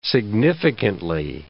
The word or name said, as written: significantly